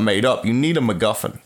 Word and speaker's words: MacGuffin: He doesn't say it like that. – He says it with an accent. – But MacGuffin? – MacGuffin. Mm. That's how it's pronounced.